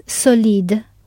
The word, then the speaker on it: solide: If solid is solide, with the stress at the end of the word, what would avid be? – Avid.